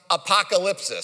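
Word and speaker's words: apokalypsis: Literally in the Greek, the Greek word is pronounced apocalypsis. So it's very close to our English word.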